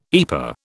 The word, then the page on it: EPA: British English